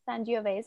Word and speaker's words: Sangiovese: So I am drinking this really cute bottle of red wine, Sangiovese. Did I say that correctly? – Sangiovese, yeah.